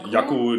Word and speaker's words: Jakku: Ja, und jetzt landen die auf dem Planeten, wie spricht man das denn aus? Jakku? – Ja, Jakku. Jakku. Genau.